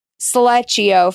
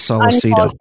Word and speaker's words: Sollecito: Sollecito, first of all, hard C. How did we not know? – It's crazy. I thought it was Sollecito.